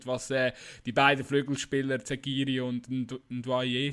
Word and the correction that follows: Ndoye: Doye, ähm, glaubst du? Ndoye, Ndoye. So ist er einmal immer ausgesprochen worden auch.